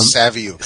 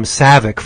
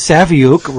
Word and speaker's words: Saviuk: because I've been calling him Savick forever. Yep. And he goes, there's a U in it. – Why would you say Savick? He said, is Saviuk rhymes with book?